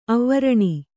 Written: ಅವರ್ಣಿ

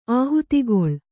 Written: ಆಹುತಿಗೊಳ್

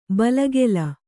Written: ಬಲಿಗಲ್ಲು

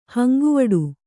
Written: ಹಂಗುವಡು

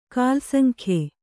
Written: ಕೆಲಸಂಗೆಯ್

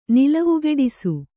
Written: ನಿಲವುಗೆಡಿಸು

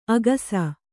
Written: ಅಗುಸೆ

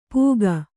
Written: ಪೂಗ

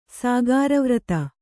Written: ಸಾಗಾರವ್ರತ

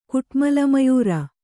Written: ಕುಟ್ಮಲಮಯೂರ

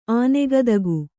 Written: ಆನೆಗದುಗ

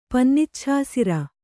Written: ಪನ್ನಿಚ್ಛಾಸಿರ

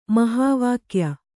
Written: ಮಹಾವಾಕ್ಯ

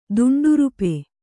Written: ದುಂಡುರುಪೆ

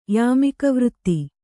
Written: ಯಾಮಿಕವೃತ್ತಿ

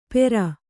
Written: ಪೇರ್

